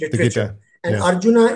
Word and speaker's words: Arjuna: Ar- Arjuna in Kuru. – Arjuna.